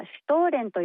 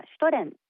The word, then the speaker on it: シュトレン: はい、そうそう、日本ではシュトーレンという表記もよく見かけるのですが、ドイツ語ではシュトレンとトを短く発音します。